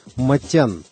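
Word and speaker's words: Motian: Армянин по происхождению, он часто говорил в интервью, что его фамилия должна была бы произноситься Матян, как полагается по правилам языка его предков. Но уж очень удачно американское чтение фамилии совпадало со звучанием слова «motion» – «движение».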